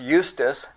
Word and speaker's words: Justus: And they proposed to Joseph, called Barsabbas, who was surnamed Justus, right? Pronounced Eustace, and Matthias.